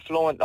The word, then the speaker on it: Flewnt: And my name, Flewnt, obviously just comes from the word Flewnt. I spell it different for hip-hop reasons.